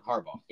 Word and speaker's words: Harbaugh: Harbaugh.